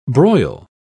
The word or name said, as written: broil